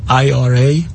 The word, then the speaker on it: IRA: بعضی از این حساب ها اسمشون هست Individual Retirement Account یا IRA و یا IRA هر جوری که تلفظش میکنید.